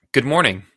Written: good morning

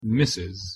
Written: mrs

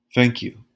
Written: thank you

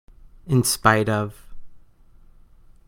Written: in spite of

